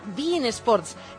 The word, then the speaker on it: beIN Sports: Sí, se escribe Bein, pero aquí se pronuncia Bein Sports.